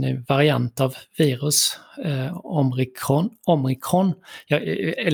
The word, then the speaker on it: omikron: Omikron. – Och så ska, du, man ska, ha betoning på det första om, o ja, och jag vet omikron.